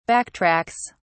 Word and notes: backtracks: British English

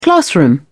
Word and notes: classroom: British English

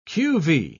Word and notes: qv: British English

